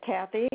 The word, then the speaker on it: Cathie: My first name is Kathy, and that's spelled C-A-T-H-I-E. Last name is Pittman, P-I-T-M-A-N.